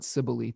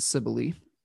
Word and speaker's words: Cybele: I've seen it pronounced a number of ways. I'm going to call it Cybele today.